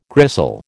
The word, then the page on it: gristle: American English